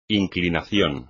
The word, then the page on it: inclinación: espagnol